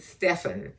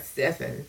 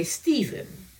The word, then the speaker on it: Stephen: Now notice Pastor said Stephen, and that's the way it's pronounced. Glory to God, Stephen. But many people say Stephen. But it's S-T-E-P, okay? So it's Stephen.